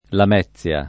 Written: Lamezia

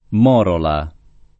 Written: morola

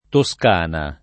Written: Toscana

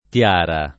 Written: tiara

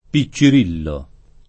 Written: piccirillo

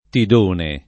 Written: Tidone

